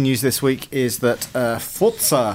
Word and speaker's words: Forza: Forza Horizon 2 was announced. – Alex is in the next room. Forza, well done for saying it right as well.